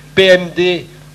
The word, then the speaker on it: Pehemde: Donc un livre dont je vous avais déjà parlé, puisqu'on l'avait vu il y a quelques mois, qui s'appelle PMD, en sachant que PMD s'écrit P-E-H-E-M-D-E.